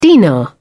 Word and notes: dinar: British English

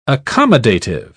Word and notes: accommodative: British English

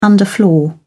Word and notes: underfloor: British English

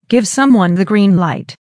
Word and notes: give someone the green light: American English

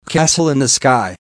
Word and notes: castle in the sky: American English

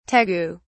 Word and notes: tegu: British English

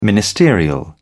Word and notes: ministerial: British English

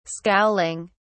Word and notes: scowling: British English

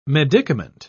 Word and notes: medicament: British English

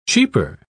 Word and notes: cheeper: British English

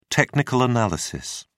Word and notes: technical analysis: British English

